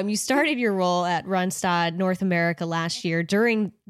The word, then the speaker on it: Randstad: Randstad or is it Randstad? – It's Randstad. We're a Dutch-owned company.